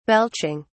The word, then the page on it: belching: British English